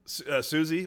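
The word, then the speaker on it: Siouxsie: Susie, which is spelled like S-I-O-U-S-I-X-E or something, and the Banshees.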